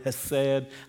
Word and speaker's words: hesed: The word loving kindness is the Hebrew word hesed. I can't say it properly. You got to say it way back in the back of your throat.